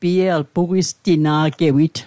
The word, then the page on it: pugwistina'gewit: Mi'gmaq